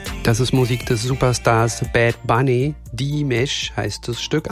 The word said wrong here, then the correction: Diles: Anna, habe ich es richtig ausgesprochen? – Nee, hast du falsch. Nein, falsch. Das heißt Diles. Sorry.